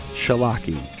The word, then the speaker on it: Wszelaki: It's spelled W-S-E-L-A-K-I, which I would have said... Wszelaki, but it's Wszelaki. – I feel like that's close. – Yeah, Wszelaki is her name.